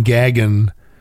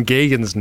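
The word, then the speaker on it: Gaghan: Now you changed the pronunciation intentionally. – He pulled it out of his can of Dr. Zog's sex wax. – I'm going to sit here and let you besmirch the pronunciation of my best friend Stephen Gagan's name.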